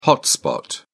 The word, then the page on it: hotspot: British English